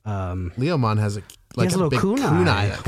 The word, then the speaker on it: Leomon: It's spelled suspiciously like lemon, but it is Leomon.